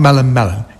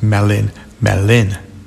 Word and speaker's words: Melin Melin: It's not Melin Melin, it's Melin Melin.